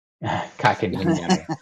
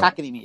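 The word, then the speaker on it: Kotkaniemi: Kakanimi. and they Josh Anderson's coming off of Kakanimi, yes.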